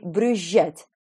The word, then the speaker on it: брюзжать: Он произнес это с двойным мягким «ж» – «визжать» и «брюзжать».